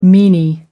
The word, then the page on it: meany: American English